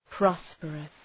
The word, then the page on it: prosperous: ‘prɒspərəs